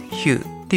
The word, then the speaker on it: Hue: HUE でヒューっていう読み方